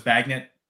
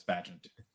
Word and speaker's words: Bagent: How do you pronounce Tyson Bagent? Is it Bagent? – I've been hearing that more. I thought it was Bagnet. Bagnet. It's not that, so it's Bagent. – Yeah, I think it's Bagent.